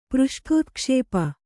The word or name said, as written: ಪೃಷ್ಠೋತ್ಕ್ಷೇಪ